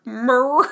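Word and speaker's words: Mirer: Um, her lawyer, Michael mm, Mirror, Myrer Mirror, it's M I R E R. Sure, mirror. – Mirror. That's how yeah. I'm going to pronounce it.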